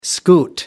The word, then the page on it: scoot: American English